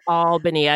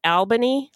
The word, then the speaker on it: Albany: Apparently, I can't pronounce Albany. That's the way it's supposed to be pronounced. Albany. I got called out for my pronunciation. I probably said Albany.